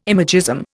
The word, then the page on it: imagism: Indian